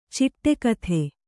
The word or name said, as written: ಚಿಟುಕೊತ್ತು